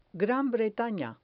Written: gran bretana

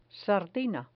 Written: sardina

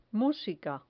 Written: musica

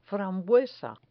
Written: frambuesa

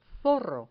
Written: zorro

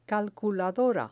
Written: calculadora

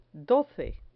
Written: doce

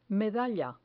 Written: medalla

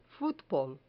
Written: futbol

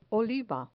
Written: oliva

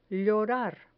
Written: llorar

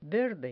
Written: verde

